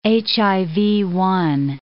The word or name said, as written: HIV-1